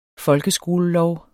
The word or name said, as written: folkeskolelov